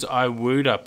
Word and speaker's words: Iwuda: And I recall who that was, and that was Iwuda, Pete. So Iwuda is spelled I-W-U-D-A, and he is an Anawan man, and Iwuda is the Anawan word for echidna.